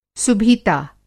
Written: सुभीता

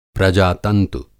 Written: प्रजातंतु

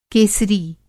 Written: केसरी

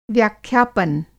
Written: व्याख्यापन

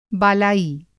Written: बालाई

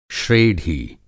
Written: श्रेढी

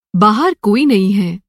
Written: कोई नहीं

